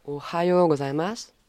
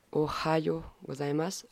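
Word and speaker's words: Ohayo gozaimasu (おはようございます): Le matin, on dira Ohayo gozaimasu. Ohayo gozaimasu. Le H s'aspire comme en anglais quand on dit hi. Quand on dit Ohayo gozaimasu.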